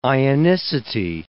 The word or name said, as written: ionicity